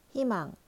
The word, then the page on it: 희망: korean